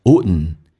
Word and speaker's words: wouldn't: 那么 D 跟 N 在一起的时候，有时候我们会发现会有一些变化，老外更喜欢说成 wouldn't D 不发音，速度加快的时候变成 wouldn't You wouldn't know Know 稍微的饱满一点，先发 A 再发 U 体验一下 Now How important 嘴巴凸出来收圆，整个舌身往回卷 Important How important you are in your own eyes.